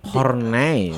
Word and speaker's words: Horney: Hornai.